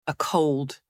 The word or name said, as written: a cold